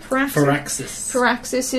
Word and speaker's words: Firaxis: This is Firaxis. Is that how you say it? – Firaxis is...